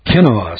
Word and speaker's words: quinoa: And I know you're going to call him up and say, I need some of that quinoa or quinoa, something like that. No, it's called quinoa. Quinoa. That's the way you pronounce it.